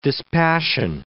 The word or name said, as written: dispassion